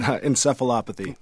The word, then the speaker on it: Encephalopathy: Encephalopathy.